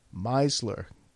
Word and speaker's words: Meisler: Now, the funny part about this is that my name is Meisler. M-E-I. Meisler. Get it?